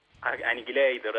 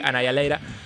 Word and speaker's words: Annihilator: Se puede pronunciar, lo podés pronunciar como el amigo Olmedo que dice Annihilator. O lo podés pronunciar como nosotros que decimos Annihilator, que está bien.